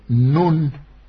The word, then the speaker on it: Нун: Что касается Навина, то есть отца Иисуса Навина, то на еврейском языке она пишется тремя буквами «Нун», «Н-У-Н», «Нун».